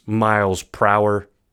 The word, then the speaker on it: Miles Prower: So if you listen to how it's actually pronounced, it's Miles Prower. Miles per hour is what they're going for.